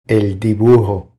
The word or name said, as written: El dibujo